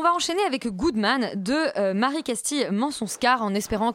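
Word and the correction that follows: Mention-Schaar: Et donc j'ai écorché son nom. On dit mention char.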